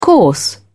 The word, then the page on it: coarse: British English